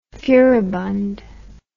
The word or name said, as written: furibund